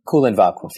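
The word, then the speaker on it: Kulen Vakuf: I'm wondering if you could explain to the listeners what happened in, I hope I get this pronounced right, Kulan Vakuf. Feel free to correct me when there's time. But Kulan Vakuf, yeah.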